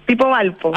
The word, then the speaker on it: Tipo Valpo: Tipo Valpo. – Ah, tipo, así ya. Sí. Y como tiene esa Y, yo no sabía si se pronunciaba tipo, typo, ok.